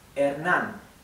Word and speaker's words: Hernán: Okay, this has an accent here, Hernán. Okay, so the H is mute, as I said before. The letter E is flat, E. The letter A is flat, A. Hernán.